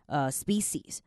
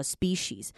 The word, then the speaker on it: species: 因为很多人就会念成 species。那当然看人啦，但通常最正确的就是 species，因为真的有一些人，就像我们可能台湾的北部人或南部人在讲台语的时候，有一些不同的发音，所以其实跟地区也是有关系的。